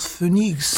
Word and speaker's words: Phoenix: Den kennen wir dann später bei der französischen Megaband Phoenix. Wie spricht man sie aus? – Ich hätte jetzt gesagt Phoenix. – Phoenix.